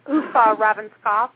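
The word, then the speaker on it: Uffe Ravnskov: Am I saying that right? – Uf Ravenskov. – Yes.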